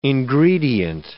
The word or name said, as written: ingredient